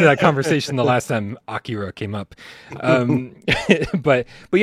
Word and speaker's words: Akira: I think it looks great because it has like this anime style, which obviously what they were going like Akira. – For. Mm-hmm. I'm sorry, how did you pronounce that? – Akira.